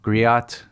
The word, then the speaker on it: griot: There's the story of Sadogo, the giant, and the continuing theme of the, I'm going to say it wrong, Griot. – That's fine. – Is that right? – I think usually you drop the T. It's actually more French than African.